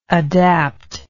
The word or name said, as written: adaptedness